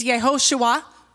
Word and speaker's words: Yehoshua: The name Joshua in Hebrew means Yehoshua, or that's how it's pronounced, and it means God is my deliverer.